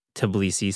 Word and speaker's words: Tbilisi: Tbilisi.